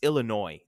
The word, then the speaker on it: Illinois: The man by the way, it's Illinois. The S is silent, Borba.